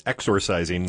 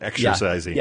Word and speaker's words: exorcising: and I don't know if I pronounced it well enough, but it's exorcising, not exercising.